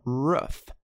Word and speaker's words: rough: Você lendo, é difícil de saber qual que é a pronúncia certa. A pronúncia é rough. Rough.